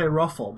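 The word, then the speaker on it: ROFL: I would occasionally say ruffle, but only to the two people I knew who were also online. And I would spell it R-O-F-F-L-E. – Good. Like truffle. – Yes, like truffle.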